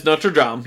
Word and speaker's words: Notre Dame: He he says it like the correct way to pronounce it, and then Michelangelo comes in and he was like, he was like, "Oh, Notre Dame! I heard like they have a good football team because like there's a football, there's a college football team called the Notre Dame, and they pronounce it not the like not the French way,